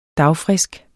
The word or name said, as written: dagfrisk